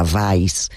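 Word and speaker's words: Weiß: Hay que decir que es un apellido alemán, la pronunciación real sería Weisz, pero bueno, se escribe con W, por acercárselo a los oyentes que no...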